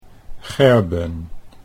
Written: Gerben

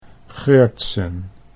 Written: Geurtsen

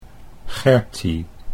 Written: Gertie

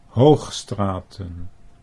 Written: Hoogstraten

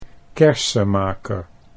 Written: Kersemaeker

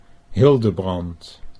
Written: Hildebrand

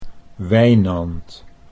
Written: Wijnand

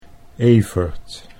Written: Evert

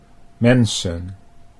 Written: mensen

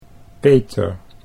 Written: Peter

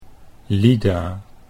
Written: Lida

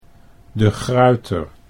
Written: De Gruyter

